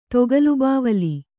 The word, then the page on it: ತೊಗಲುಬಾವಲಿ: togalu bāvali